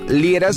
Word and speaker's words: Liera: Y a tu servidor, pues me buscas por mi nombre, Fernando Lira. Se escribe L-I-E-R-A.